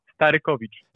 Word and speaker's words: Starykowicz: And, and, so, so, I'll tell you how do you pronounce it in like in the real Polish. It's Andrew Starykowicz. Starykowicz.